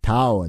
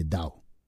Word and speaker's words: Dao: Обычно вы произносите это как Tao De Zin. Я не знаю, почему это слово пишут через Т. Я никогда не слышал, чтобы его произносили иначе, нежели Дао.